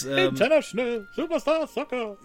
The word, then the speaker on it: International Superstar Soccer: Das darf man auch nur so aussprechen übrigens. Und International Superstar Soccer.